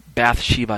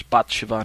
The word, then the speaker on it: Bathsheba: Bathsheba, I think is how it's pronounced in English, Bathsheba in Hebrew.